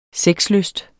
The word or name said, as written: sexlyst